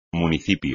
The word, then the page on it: municipio: espagnol